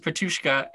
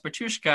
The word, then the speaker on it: Petushka: The teacher mispronounces Petushka, and that's when Mina says, it's actually, you know, it's pronounced Petushka, and in Bahavia it means a foolish shepherd who doesn't know a sheep from his yak.